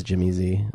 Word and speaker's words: Jimmy Z: I always pronounced it Jimmy's. How would you pronounce it? – I thought it was Jimmy Z, but I don't. – Probably was Jimmy Z.